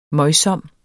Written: møjsom

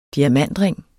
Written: diamantring

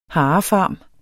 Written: harefarm

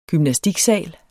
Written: gymnastiksal

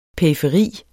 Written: PERIFERIERNES

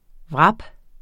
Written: wrap

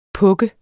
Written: pukke

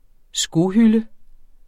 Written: skohylde

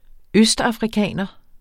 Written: østafrikaner

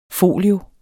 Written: folio

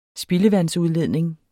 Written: spildevandsudledning

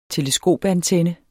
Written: teleskopantenne

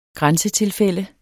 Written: grænsetilfælde

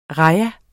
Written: rajah

